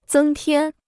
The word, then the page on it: 增添: zēng tiān